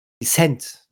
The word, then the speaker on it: Descent: aber spricht natürlich nicht dafür, dass man The Descent, äh, Descent mal wieder aufholen sollte. Äh, auffrischen sollte. – Aufholen. Und, beim Titel die zweite Silbe betonen sollte. – Dezent, ne? – Ja.